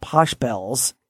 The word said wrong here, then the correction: Pachelbel: Pachelbel. – Pachelbel.